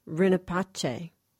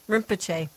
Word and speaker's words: Rinpoche: Her new film, called For the Benefit of All Beings, is about a gentleman named Garchen Rinpoche. I hope I said that correctly. Did I say that right? – No, it's Rinpoche. – Rinpoche.